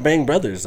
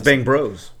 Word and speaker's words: Bang Bros: And nobody says Bang Brothers. – No, it's not Bang Brothers, though. It's Bang Bros.